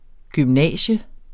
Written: gymnasium

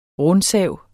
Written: rundsav

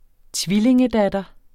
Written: tvillingedatter

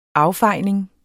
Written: affejning

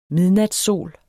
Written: midnatssol

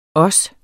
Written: også

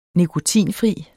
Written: nikotinfri

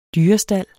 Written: dyrestald

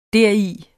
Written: deri

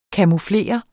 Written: camouflere

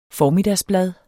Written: formiddagsblad

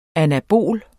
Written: anabol

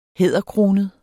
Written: hæderkronet

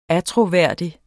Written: attråværdig

